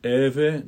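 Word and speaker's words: Evae: I've heard the H pronounced in the heve but I usually keep it silent. Mutans eve nomen.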